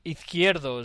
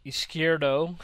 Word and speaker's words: Izquierdo: And we're going to have Guillermo Izquierdo is how you pronounce it in Spanish, Izquierdo in English, from the Spanish thrash band Angelus, Apatrida.